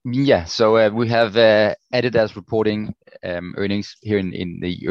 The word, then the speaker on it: Adidas: In equities, one of them you mentioned this morning on the internal meeting, was Adidas, Americans say Adidas.